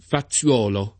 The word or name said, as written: Faziuolo